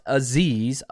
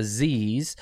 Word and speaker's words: Azeez: Now, before we jump into his college and kind of what he was able to accomplish there, his name is pronounced Azeez, right, U-H, if you look at the pronunciation, Azeez, and it's Alshire. Alshire. So like if you think about like the Hobbits, right? The Shire, Alshire. So Azeez Alshire is the way you pronounce it, which pronouncing things is not my thing.